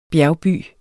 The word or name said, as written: bjergby